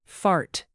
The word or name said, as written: fart